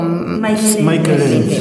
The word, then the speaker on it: Michael Ende: El autor de La historia interminable, no sé si se dice Michel Ende o Michael Ende. Sí.